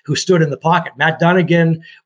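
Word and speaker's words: Dunigan: I can't pronounce that last name. – Matt Dunnigan.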